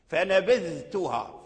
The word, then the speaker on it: فنبذتها: إذن فنبذتها من كلمات من الألفاظ التي اتفق فيها ورش وقالوا على إظهار الذال عند التاء، فنبذتها أي تنطق بالذال ثم ترجع بلسانك إلى وصول الثنايا فتخرج التاء من مخرجه.